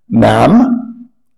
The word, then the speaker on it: ma'am: I would say, ma'am, rhymes with jam, jam and bread, not the American jam, I'm in A.